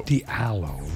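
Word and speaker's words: Dalio: Diallo.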